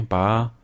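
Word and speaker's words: Bá: but Gabriel Bay, Ba. – Um, Gabriel Ba, yes. Yes.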